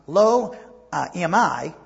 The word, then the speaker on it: Lo-Ammi: And that one son, Lo-Ammi, that's how you pronounce it, which means not my people, and his daughter was given the name Lo-Ruhama.